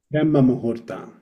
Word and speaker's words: Brahma Muhurta: Brahma Muta. – Brahma Muhurta.